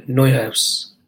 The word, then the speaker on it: Neuhaus: young central midfielder by the name of Neyhouse, Newhouse. You're the man for pronunciations, as long as they're not Portuguese. Florian Neuhaus. Florian Neuhaus.